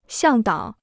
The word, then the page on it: 向导: xiàng dǎo